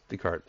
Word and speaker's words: Descartes: what was it, Descartes? I'm probably butchering the name. – Descartes. – Descartes, thank you.